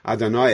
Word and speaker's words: Adonai: Adonai Echad. When we pronounce Shema, even though it's spelled this way, we always say it Adonai. We pronounce it as Adonai, master.